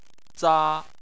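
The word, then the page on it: za: tone 1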